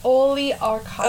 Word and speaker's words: oligarchical: But it's archaical. Archaical. – It's oligarchical. – Okay, so it's spelled oligarchical. Archives. No, that'd be a chi.